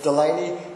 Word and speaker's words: Delaney: My last name is actually Delaney, which might seem a bit odd. It's not pronounced Dalani, it's Delaney. I'm actually full-blooded Chinese-Irishman, just to let you know that.